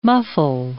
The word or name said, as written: muffle